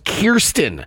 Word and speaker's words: Kirsten: The Spider-Man star revealed that her first name is pronounced Kirsten.